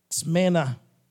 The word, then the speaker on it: Smyrna: Smyrna.